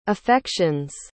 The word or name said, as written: affections